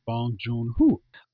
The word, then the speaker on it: Bong Joon-ho: And the Parasite's director, let's say his name is pronounced as Bong Joon-ho.